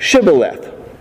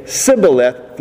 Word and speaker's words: Shibboleth: They said, Shibboleth. Then he would say, Sibboleth, for he could not pronounce it correctly.